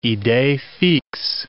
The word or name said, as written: idee fixe